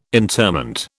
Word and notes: interment: British English

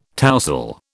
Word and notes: tousle: British English

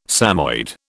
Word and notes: Samoyed: British English

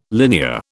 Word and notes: linear: British English

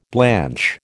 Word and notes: blanch: American English

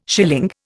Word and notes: schilling: Indian

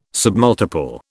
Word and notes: submultiple: British English

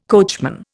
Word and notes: coachman: Indian

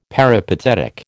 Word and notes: peripatetic: American English

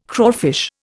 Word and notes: crawfish: Indian

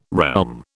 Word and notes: realm: British English

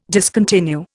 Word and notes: discontinue: Indian

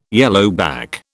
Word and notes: yellowback: British English

